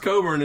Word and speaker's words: Cockburn: It's pronounced Coburn, and it's spelled Cockburn.